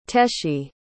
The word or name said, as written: teshi